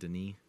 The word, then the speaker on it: Denis: Denis? He's French. He's French Canadian.